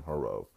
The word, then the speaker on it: Herro: I think that's how you pronounce his name, Herro.